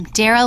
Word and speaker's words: Dara: I'm Dara like Sarah.